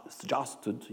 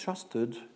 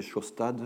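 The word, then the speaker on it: Sjaastad: Alors, comment prononce-t-on ce nom les, Américains disent Justed, ils essaient de prononcer. Euh, les Anglais disent Shasted les Norvégiens, les disent Shostat. Donc moi, je vais prononcer Shostad, un peu comme Shostakovich. Ça va simplifier les choses.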